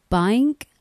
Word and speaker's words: Baynk: That was, I never know how to pronounce that. Bank, B-A-Y-N-K, and Cup Sports.